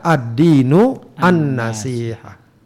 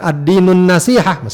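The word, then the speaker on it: ad-dinu an-nasihah (الدين النصيحة): Makanya bacanya pun lebih dekat atau lebih tepat kalau dibaca ad-dinu an-nasihah. Bukan ad-dinun nasihah, meskipun juga tidak salah mutlak ya.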